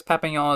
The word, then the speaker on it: Papillon: It's Papillon flavor, P-A-P-I-L-L-O-N, which is apparently French, and it's apparently pronounced Papillon.